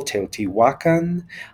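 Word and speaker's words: Teotihuacán: So it is, for example, the emphasis ought to be on the second to last syllable, Teotihuacan. The Mexica language or the Aztec language,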